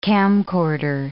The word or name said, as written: camcorder